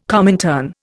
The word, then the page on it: Comintern: Indian